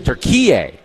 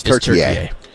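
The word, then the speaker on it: Türkiye: Turkey. Turkey. – Oh, I thought the emphasis was on the key. – It's the emphasis on the yay. It's on yay.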